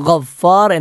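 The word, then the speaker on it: ഗഫ്ഫാർ (غفار): അൽ ഗഫ്ഫാർ എന്നത് അവിടെ ഗ ആണ് ശ്രദ്ധിക്കേണ്ടത്. ഗ എന്നത് ഞാൻ പറഞ്ഞു തൊണ്ടയുടെ മുകൾ ഭാഗത്ത് നിന്നും പുറപ്പെടേണ്ട അക്ഷരമാണ്. ആ ഫും റാവും പല ആളുകളും തെറ്റിക്കാറില്ല, എല്ലാവർക്കും പറയാൻ എളുപ്പമുള്ള അക്ഷരങ്ങളാണ്.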